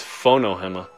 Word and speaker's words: Fonohema: Kinilau, K-I-N-I-L-A-U. Say the last name again. – Fanuhema. – F-A-N-U... H-E-M-A. – Hold on. The way you said it may throw you off. It's Fonohema. – Oh! Is it F-O-N-O? H-E-M-A.